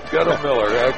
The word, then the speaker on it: Goettemoeller: I've often asked Kurt, now how do you pronounce G-O-E-T-T-E-M-O-E-L-L-E-R? And he said, it's a lot simpler than it looks. Don't worry about all those vowels and all those consonants. It's just Guttemiller. – There you go. – M-I-L-L-E-R on the end.